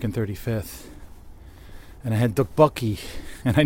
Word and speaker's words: tteokbokki: Dukbokki. The way it's spelled, it says... It's like T-T-E-O... K-E-O-E-I-U. I don't know how to spell this thing. But I remember super researching it. It's dukbokki. So when I ordered it, I'm like, hey, can I have the dukbokki?